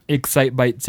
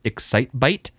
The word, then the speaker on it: Excitebike: I'm going to say this wrong. I just keep saying Excitebite. It's going to happen. So, you know, 3 points to anyone out there who counts the number of times I've said the name wrong already.